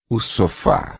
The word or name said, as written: sofá